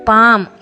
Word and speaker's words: PALM: GUARD ಗಾರ್ಡ್, BALM ಬಾಮ್, PALM ಪಾಮ್, ಈ ಥರದ ಒಂದಷ್ಟು ಪದ ಹೇಳಿದೆ. ಇಲ್ಲಿ ನೋಡಿ, GUARD ಗಾರ್ಡ್ ಆರ್ ಉಚ್ಚಾರ ಆಗಲಿಲ್ಲ, PALM ಅಲ್ಲಿ ಎಲ್ ಸೈಲೆಂಟ್ ಆಗ್ಬಿಡ್ತು.